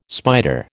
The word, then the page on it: spider: American English